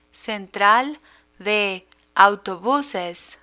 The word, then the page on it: central de autobuses: spanish